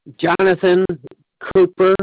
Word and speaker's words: Jonathan Cooper: Jonathan Cooper. – Spell that, please. – J-O-N-A-T-H-A-N. – Okay, last name? – C-O-O-P-E-R.